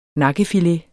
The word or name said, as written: NAKKEFILETER